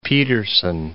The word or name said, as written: Peterson